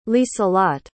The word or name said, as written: lieselotte